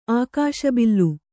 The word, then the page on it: ಆಕಾಶಬಿಲ್ಲು: ākāśa billu